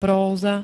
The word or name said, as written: próza